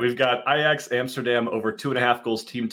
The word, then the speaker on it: Ajax: Ajax.